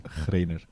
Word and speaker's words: Greiner: Het is een gekke spelling, maar volgens mij spreek je het uit als Greiner.